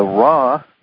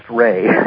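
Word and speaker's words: Ra: Most folks in the audience will know her through the Law of One series, the Raw material. Or is that pronounced Ray? – Well, it is in French. – Okay. – But we say Raw. – So it's Ray in French.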